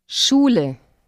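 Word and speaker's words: Schule: Сочетание звуков S, C, H напоминает звук, который произносится, когда нужно, чтобы кто-то успокоился. – Ш. Шуле.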